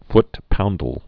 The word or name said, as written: foot-poundal